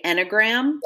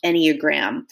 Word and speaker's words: Enneagram: Yeah, I always hear people say Enneagram. Yes. So if you've heard that, guys, this is what this is. And in researching it, Jamie and I found out that it's actually pronounced Enneagram.